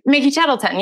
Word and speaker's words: Tettleton: Mickey Tettleton.